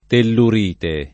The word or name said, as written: tellurite